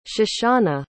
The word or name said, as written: shoshannah